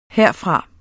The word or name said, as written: herfra